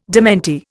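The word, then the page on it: démenti: Indian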